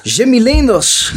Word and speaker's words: Jimmy Leenders: Jimmy Leenders, Roda Watcher van Dagblad De Limburger. Ja. – Zeg ik dat goed? – Dat zeg je helemaal goed.